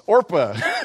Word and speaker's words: Orpah: Even Oprah pronounces the name wrong. It's Orpah.